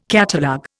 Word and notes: catalogue: Indian

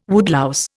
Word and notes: woodlouse: Indian

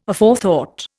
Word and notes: Aforethought: Indian

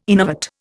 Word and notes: innervate: Indian

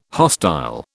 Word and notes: hostile: British English

